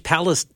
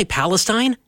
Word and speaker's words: Palestine: I want to say Palestine. It's spelled. I spent a couple of years down in Iowa, and they have Nevada, spelled the same as Nevada, but it's pronounced Nevada. And I'm having the same issue here. It's Palestine, but Palestine.